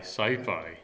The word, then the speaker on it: Syfy: How do you pronounce it now? S-Y-F-Y, sci-fi.